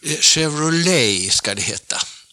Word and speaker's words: Chevrolet: Det heter Chevrolet, Chevrolet, Chevrolet och inget annat. Men fortfarande så säger jag kanske Chevrolet. Jag är lite för svenska där, va. – Kör du själv en Chevrolet hit ut när du pendlar från stan? – Nej, det är bara Saab som gäller idag.